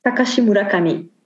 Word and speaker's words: Takashi Murakami: I've always said Takashi Murakami, but you were telling me it's, let's see if I can get it right this time, Takashi Murakami. – Takashi Murakami.